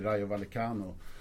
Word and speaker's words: Rayo Vallecano: Och då ska vi förtydliga för lyssnarna, det är Rayo Vallecano som det heter då, kanske inte uttalas så, men...